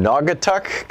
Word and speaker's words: Naugatuck: David in Naugatuck, Connecticut.